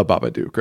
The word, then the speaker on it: Babadook: So it's the Babadook, right? – Yes.